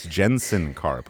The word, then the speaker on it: Jensen Karp: Well, first of all, it's not Jetson Karp. It's Jensen Karp, which I already said that.